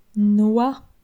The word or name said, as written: Noix